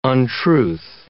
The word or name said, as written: untruth